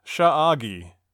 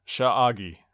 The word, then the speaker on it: Sha'agi: Sha'agi. So that is no coincidence. They threw that in there. It's spelled S-H-A apostrophe A-G-I. Sha'agi.